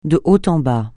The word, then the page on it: de haut en bas: francais